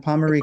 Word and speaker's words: Pomarico: Hey, Michael, Pomarico or Pomarico? – No, you said it right the first time, Pomarico.